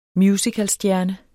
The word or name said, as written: musicalstjerne